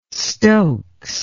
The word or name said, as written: stokes